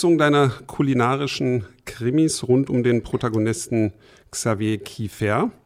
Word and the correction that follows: Kiefer: Jetzt habe ich es doch französisch oder luxemburgisch ausgesprochen, aber es ist Kiefer, ne? – Es ist tatsächlich Kiefer, genau.